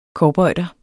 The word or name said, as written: cowboyder